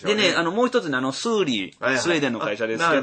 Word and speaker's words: Thule: あれのマークでね、みんなしてありますよね、スーリー。あれね、読み方ね、スーリーって読むんですよ。ちょっと読めないんですよ、これ。 THULE で、なんて読むんかなと思って、あれスーリーと、まあ日本語では読むんですけど。